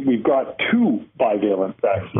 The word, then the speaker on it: bivalent: So what about this new bivalent booster? How am I doing with the pronunciation, by the way? – It's perfect. Roy, you're a pro. Bivalent.